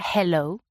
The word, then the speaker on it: hello: Entonces, ¿cómo hay que pronunciar esa H? – Entonces sería hello. – He. – Así hello. Como, como dejando un poquito, como si nos calentáramos un poquito las manos, ¿no? Con el aire sale, pero nada más.